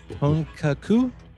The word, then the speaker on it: Honkaku: All right, and let's see if I can do this correct, of Honkaku. – That's pretty good.